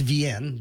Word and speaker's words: Wien: If you go to Vienna in Austria, it's Vienne, W-I-E-N.